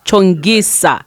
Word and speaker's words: Chonguica: Chongguica.